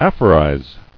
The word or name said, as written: Aphorize